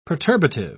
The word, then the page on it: perturbative: British English